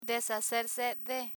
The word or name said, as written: deshacerse de